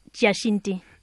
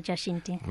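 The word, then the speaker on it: Giacinti: Jacinti. – Jacinti. – Yes, Valentina Jacinti.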